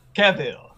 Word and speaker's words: Cavill: Cavill! – Cavill. – Two syllables.